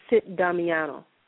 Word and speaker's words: Sit Damiana: SITTDAMIANA, but it's pronounced Sit Damiano. That's how the people of Nubia pronounce Sit Damiano.